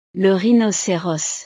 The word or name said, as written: le rhinoceros